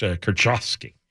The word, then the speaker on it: Kurczewski: K-U-R-C-Z-E-W-S-K-I. – Kurchowski. – Yeah, because it's C-Z. – That's a ch sound. And then the W would be a V sound, so it's Kurchowski.